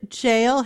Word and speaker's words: gaol: And I say the word jail hesitantly because it is spelled G-A-O-L. Tell us how you pronounce it and why it's called that and a little bit about its history. – Yeah, absolutely. So you're pronouncing it correctly. G-A-O-L is just the old English spelling, so we just call it the old jail.